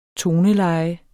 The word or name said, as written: toneleje